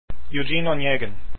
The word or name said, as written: eugene onegin